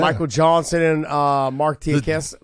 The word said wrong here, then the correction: Diakiese: How do you say his last name? Tiakesa. Diakiese. Diakiese. Diakiese.